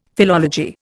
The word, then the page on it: philology: Indian